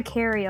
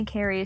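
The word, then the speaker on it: Cari: Well, I go by Carrie a lot. – You? – Yeah, a lot of my friends call me Carrie.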